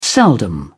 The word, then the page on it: seldom: British English